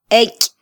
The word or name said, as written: eky